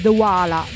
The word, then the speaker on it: Dewaele: Ho scoperto come si pronuncia: Stefan e David Douala da Ghent,